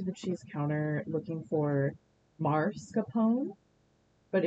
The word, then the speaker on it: mascarpone: Really, in Italian, you pronounce the E at the end. It would be mascarpone, but it is mascarpone at an American setting.